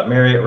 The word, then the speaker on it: Marriott: Marriott rhymes with chariot.